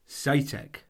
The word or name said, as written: seytek